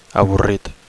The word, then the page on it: avorrit: Catalan